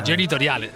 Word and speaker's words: genitoriale: Sì, genitoriale è la pronuncia corretta,